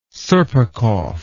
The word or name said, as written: serpukhov